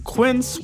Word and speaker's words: Quince: Today Explained support today comes from Quince, which rhymes with since, but is spelt with a Q-U.